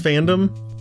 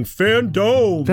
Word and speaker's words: Fandome: Is it supposed to be pronounced fandom? – It's Fando. I believe it's spelled D-O-M-E. – No, I've never heard it said, by like a person who wasn't going Fandome. – That's the only way to say it.